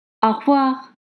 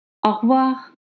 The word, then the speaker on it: au revoir: Au revoir. Au revoir.